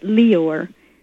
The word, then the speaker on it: Lyor: A man named Lior Cohen, L-Y-O-R, and he pronounces it Lior, But he controlled all the black rapper groups and would blackmail them with having to eat their own scat on film.